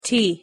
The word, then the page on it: t: English